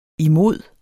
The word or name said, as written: imod